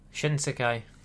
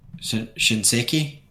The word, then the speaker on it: Shinsekai: Obviously, Into the Depths doesn't count because that's a subtitle, but how would you say the top one? – Shinsekai. – Shinseki? No? – No, Shinsekai.